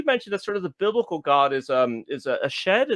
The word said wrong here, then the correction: Shaddai: Is that how you say it? – Yeah. So one of the names for the Bible has many names for God. One of them is Shaddai, which actually etymologically is probably connected to breasts.